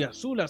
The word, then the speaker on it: Azula: Sí, Azula, sí.